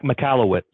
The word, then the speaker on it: Michalowicz: So now, getting into this a little bit further, we started talking about this a while ago, but the Mike Michalowicz, is that how I say his last name? – Right? Mike Michalowicz. – Michalowicz. – There you go.